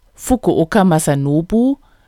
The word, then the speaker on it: Fukuoka Masanobu: Fukuoka Masanobu wird genauso geschrieben wie ausgesprochen.